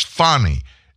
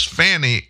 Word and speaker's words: Fani: You know by now the name Fannie Willis, F-A-N-I. That's the wrong pronunciation. I know she corrected a lot of people. It's Fannie, F-A-N-I. If it was Fanny, it would be F-A-N-N-I.